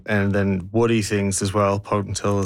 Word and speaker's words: potentilla: Are you familiar with the potentilla bush? Have I pronounced that correctly? – Yes, potentilla, that's right.